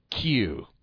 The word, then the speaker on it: Kieu: His first name is P-H-U-C. And his last name is K-I-E-U. – How's that pronounced, that K-I-E-U? – He is Mr. Q.